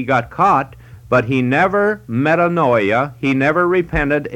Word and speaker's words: metanoia: It is another Greek word which is pronounced metanoia or metanoio, and that means to change your mind or to think differently.